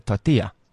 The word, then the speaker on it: tortilla: It's a tortilla. – Tortilla. – Because the double L becomes a Y.